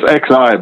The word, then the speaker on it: Xi: It's Z. It's XI, but it's pronounced Z, I believe.